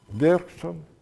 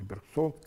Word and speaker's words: Бергсон: Платон, Бергсон, или Бергсон, как по-разному произносит.